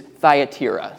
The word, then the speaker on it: Thyatira: I think that's how you say that. I've, lis- I've, listened to a bunch of pastors say it, they all say it different ways. The official pronunciation on YouTube is Thyatira.